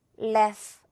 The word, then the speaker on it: laugh: 그리고 laugh 이렇게 입 짧게 벌리면 안 되고요. 이거 우리 배웠던 고무줄 발음이거든요, 여러분. 이렇게 길게 늘어나게 위아래로 laugh 이렇게 좀 끌어주세요.